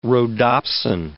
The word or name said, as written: rhodopsin